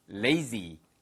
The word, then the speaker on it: lazy: 그러면 lazy. lazy. 아시겠죠? 자, 됐고요. z 발음 하나 살려보세요. lazy 아니고요. 위치아와 아래치아가 살짝 갈리면서 혀 끝을 딱 붙여보세요. z, z, z. 아시겠죠? 그래서 lazy.